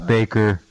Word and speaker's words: Bakker: Really, it's Baker.